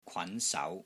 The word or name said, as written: KWAN2 SAU2